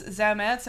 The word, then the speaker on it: Zametz: Okay, so James Zametz, am I saying it right? – Yeah. – Okay, good. You're going to have to spell that for me, because I screwed that up the first time. – Z-A-M-E-T-Z.